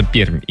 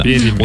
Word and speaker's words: Пермь: Да, и даже Руслан пишет, Пермь, выделяя между R и M большой мягкий знак. Как бы в официальном названии не пишется, но произносится Пермь именно. – Пермь.